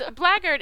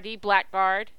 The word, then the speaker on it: blackguard: It's blaggard. It's actually B-L-A-C-K-G-U-A-R-D, blackguard. – Oh, okay. – That's how it looks, blackguard, but it's pronounced blaggard.